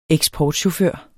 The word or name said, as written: eksportchauffør